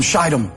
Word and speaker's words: Shittim: Then Joshua, son of Nun, secretly sent two spies from Shittim. If you ever read that out loud, be careful that you pronounce that correctly. You want to use a long I.